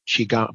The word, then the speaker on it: Qigong: And Qigong is spelled kind of, it's Chinese, and it's spelled Q-I-Q-O-N-G, Qigong, but it's pronounced Qigong.